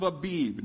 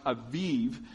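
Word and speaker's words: Aviv (אביב): Do this at the appointed time in the month of Abib. Now, it's actually pronounced Aviv, which is spring. Spring is a Hebrew, Aviv is the Hebrew word for spring.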